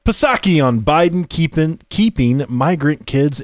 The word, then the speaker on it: Psaki: I'm going to tell you about, is this Psaki? I think is how you say it. – Psaki Sake. – Is it Psaki? – Not to be confused with the drink Sake.